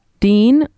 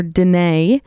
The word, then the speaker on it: Dene: These First Nation cultures include Plains Cree, Swampy Cree, Woodlands Cree, Dean or Dene, people say it's pronounced different way, Salto, Dakota, Nakota, and Lakota.